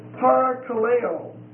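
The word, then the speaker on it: Parakaleo (παρακαλέω): The Greek is parakaleo. Parakaleo. P-A-R-A-K-A-L-E-O. It's a long O. Parakaleo.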